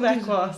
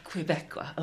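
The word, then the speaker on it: Québécois: on Kibeswa cinema. Am I saying that right? Kibeswa? – It's the imagination. – Quebecois cinema. – Quebecois. Okay, yeah. Perfect.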